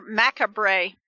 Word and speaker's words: macabre: Or macabre. Carbra. Oh, what? – Macabre. Macabre, the R is silent there.